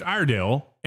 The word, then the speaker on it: Iredale: They await the winner of West Iredale and Ashe County. Did I mispronounce that, Mike? – No, you're correct on those.